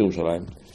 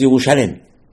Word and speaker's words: Yerushalayim: We pronounce it Yerushalayim. But it says Yerushalem.